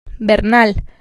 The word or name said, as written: Bernal